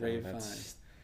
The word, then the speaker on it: Ralph Fiennes: Like, Ray Fiennes, who is the most confusing to pronounce name ever, he played Voldemort, and his name written out is Ralph Fiennes. – Yeah. – But it's Ray Fiennes? – Yeah, you pronounce it like Ray Fiennes.